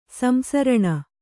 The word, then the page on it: ಸಂಸರಣ: samsaraṇa